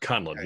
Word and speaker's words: Conlon: And today we have another great guest on the podcast, Robb Conlon, and I should have pronounced that better, but I feel like I stumbled. But Conlon, is that right? – That's correct. Conlon, yes.